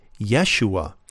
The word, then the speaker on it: Yeshua: Now, the proper Jewish pronunciation of that name is Yeshua.